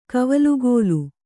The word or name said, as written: ಕವಲುಗೋಲು